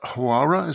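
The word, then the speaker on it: Huwara: So let's talk first about Hawara. Is that how you pronounce the town? – Yes.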